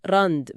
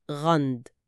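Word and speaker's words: Rand: بالظبط، ممكن تتنطق راند براء عادية، لكن النطق الأكثر شيوعاً هو غند بصوت حلقي خفيف.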